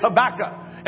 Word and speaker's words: Habakkuk: Some have called him Habakkuk or Habakkuki, but really the right pronunciation is Habakkuk.